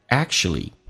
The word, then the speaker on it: actually: 音标叫 actually, 但是生活当中首先 u 可以发成 a, ch 发成 sh, 同时在美式发音那个 k 是不用发音的，就变成 actually, 你要发现 actually 两回事 right?